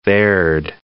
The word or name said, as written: there'd